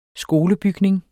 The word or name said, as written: skolebygning